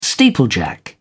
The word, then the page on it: steeplejack: British English